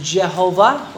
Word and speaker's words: Jehovah: The Masoretic text has the vowel pointings that show us that the pronunciation the pronunciation of the name is Jehovah or Yehovah. Sometimes the J could be Y, sometimes the V could be W.